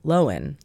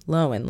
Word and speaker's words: Lohan: Lindsay Lohan, for example, when you see her in interviews, she'll say Lohan, Lohan, Lindsay Lohan. But it doesn't stop people from saying straight back to her face, Lohan.